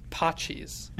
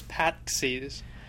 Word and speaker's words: Patxi: you pronounce it Pachis. And so that's how I know.